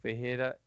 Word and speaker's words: Ferreira: So if anybody wants to check my Instagram, it is at Marcelo Ferreira HQ.